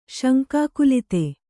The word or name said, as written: ಶಂಕಾಕುಲಿತೆ